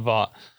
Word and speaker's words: Vought: Vought.